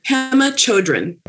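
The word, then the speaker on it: Pema Chödrön: Pema Chodron. – Okay. Pema Chodron. – Mm-hmm. – What about that? What about that book touched you? – So Pema Chodron is a Buddhist monk.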